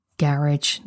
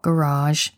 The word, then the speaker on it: GarageBand: And then I got editing, figured out how to use GarageBand, the pronunciation of which I am well aware is hilarious to most Americans who might be more inclined to say GarageBand, but I'm a Brit, I say garage, not garage.